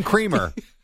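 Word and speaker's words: Kremer: Dean Creamer. – Dean Creamer. – I could be wrong. I just think they pronounce it Kramer.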